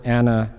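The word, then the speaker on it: anoetos (ἀνόητος): In fact, one of my Greek commentaries said this word anoetos, Christine wants me to spell that, A-N-O-E-T-O-S, with an accent over the E, anoetos.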